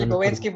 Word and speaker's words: Lipovetsky: Sí, se eh, pronuncia Lipovetsky, porque Lipovetsky yo es un apellido como polaco, una cosa así.